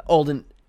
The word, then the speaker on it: Alden: So our story begins with uh, David, played by O'Shea Jackson Jr., aka Ice Cube's son, and Eddie, uh, played by Aldrin uh, Alden Ehrenreich. I always mispronounce his name, but you may know him from Solo, the Han Solo movie.